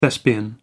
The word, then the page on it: thespian: American English